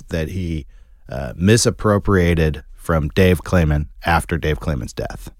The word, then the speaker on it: Kleiman: Am I mispronouncing his name? – That's how it was said to me by someone involved in the case. Kleiman.